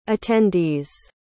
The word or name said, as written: attendees